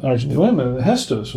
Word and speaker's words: Gestos: The Malbec, it's Gestos, G-E-S-T-O-S is pronounced Gestos.